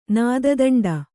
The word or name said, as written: ನಾದದಂಡ